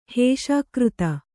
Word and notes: ಹೇಷಾಕೃತ: kannada

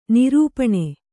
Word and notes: ನಿರೂಪಣೆ: nirūpaṇe

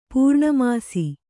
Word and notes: ಪೂರ್ಣಮಾಸ: pūrṇa māsa

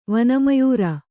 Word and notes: ವನಮಯೂರ: vana mayūra

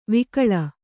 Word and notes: ವಕುಳ: vakuḷa

